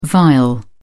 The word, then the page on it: viol: British English